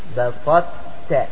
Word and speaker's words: بسطت: بسطت واضح؟ يأتي بصفة الإصباغ في التاء، هذا يسمى إضغامه ناقص، إضغام ناقص، فتنطق الطاء خفيفة.